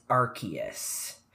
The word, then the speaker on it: Arceus: Like, yes, it is Arceus.